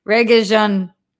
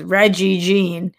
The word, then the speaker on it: Regé-Jean: I think it's pronounced Regé-Jean. Regé-Jean. Right? Not Jean. I don't fucking know. You guys know I butcher this every time. It just is what it is. You, at least I understand that it's reggae now when I thought it was Reggie Jean.